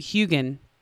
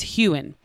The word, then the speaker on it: Heughan: The internet went bananas because I apparently pronounce his name Heughan and it's Heughan. So forgive me, fans of Sam Heughan, forgive me. I have to break my habit of calling him Heughan. It's Heughan.